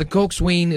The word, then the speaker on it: coxswain: In modern rowing, the coxswain is the strategist, navigator, motivator, and field commander of the crew, mga kapuwersa. Ang pagbasa po ng coxswain, pero pag pronounce po nito ay Coxswain.